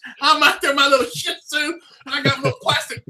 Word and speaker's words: Shih Tzu: I said, there's no T in it. Shih Tzu. Say it right.